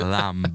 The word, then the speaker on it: lamb: Lamb. – Oh, yeah. It's not pronounced the B in lamb. You've got to pronounce the B now.